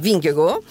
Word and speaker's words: Vingegaard: Jonas Vingegaard, se la diciamo all'italiana, se invece manteniamo l'accento danese Vingego,